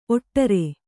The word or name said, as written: ಓಡ್ರ